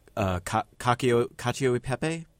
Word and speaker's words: cacio e pepe: but I have to ask about cacio e pepe.